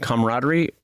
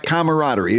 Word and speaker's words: camaraderie: Wait, side note, did you introduce an extra vowel in camaraderie? – Camaraderie. Camaraderie. It's camaraderie.